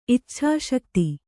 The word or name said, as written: ಇಚ್ಛಾಶಕ್ತಿ